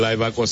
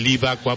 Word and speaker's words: Live Aqua: Antes le pusimos el Live Aqua porque así lo pensamos. Nos dimos cuenta que los americanos lo pronuncian como Live Aqua. Entonces dimos este cambio y es Live Aqua.